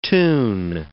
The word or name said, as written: tune